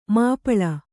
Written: ಮಾಪಾಳ

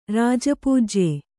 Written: ರಾಜಪೂಜ್ಯ

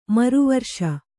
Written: ಮರುವರ್ಷ

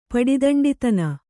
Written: ಪಡಿದಂಡಿತನ

